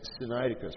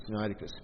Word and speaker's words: Sinaiticus: One of the most important of these is Codex Sinaiticus, or Sinaiticus. There's a number of ways to pronounce it. I just go with the simple Sinaiticus.